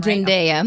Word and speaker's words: Zendaya: Did I say her name Zendaya.